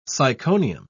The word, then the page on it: syconium: British English